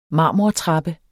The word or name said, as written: marmortrappe